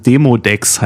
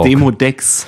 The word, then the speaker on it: Demodex: Ich habe es falsch ausgesprochen. Demodex heißt es. Na, dann kenne ich's. – Also Demodox. Nicht zu verwechseln mit Desodox. – Demodex.